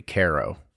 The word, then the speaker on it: Cairo: And being Brad says that we're saying it wrong. It should be Cairo.